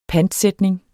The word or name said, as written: pantsætning